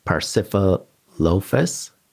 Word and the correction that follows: Parasaurolophus: Am I saying that correctly? – Oh, the Parasaurolophus or? – There, nice. That's bingo.